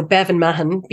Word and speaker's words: Bevin Mahon: So Bevin Mahon, B-E-V-I-N-M-A-H-O-N.